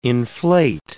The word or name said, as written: inflate